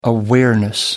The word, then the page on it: awareness: American English